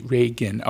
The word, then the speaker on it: Ragin: Can you spell it? – E-L-L-I-E. And her last name is R-A-G-I-N. – R-A-G, Reagan. Okay, mm-hmm. I got it. I was thinking like the president, but it's not spelled that way.